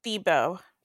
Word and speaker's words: Thibault: I've heard it's Thebo. Thebo. Thebo. Thebo. Like, oh, the AULT is silent. Because yeah, because French.